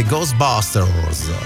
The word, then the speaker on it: Ghostbusters: Con la pronuncia inglese Ghostbusters. Brava.